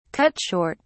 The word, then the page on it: cut short: British English